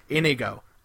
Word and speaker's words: Inigo: I've never ever heard anyone pronounce it Inigo. – I'm that guy. – That's weird. – Inigo. – His name is Inigo. – Inigo. Inigo. – Inigo Montoya. – Inigo. I pronounce things my own way. – It's not Indigo without the D.